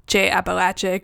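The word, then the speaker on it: Ché Apalache: I did some fact checking, and as it turns out, we were pronouncing Che Appalachia correctly.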